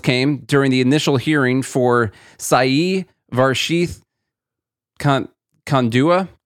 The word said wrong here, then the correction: Kandula: I don't know how you say it. – Kandula. – Yeah, whatever way that you say that.